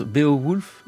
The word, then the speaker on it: Beowulf: Comment on le prononce ? Beowulf ? – Oui, c'est ça. Oui.